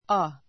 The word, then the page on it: a: ə; ア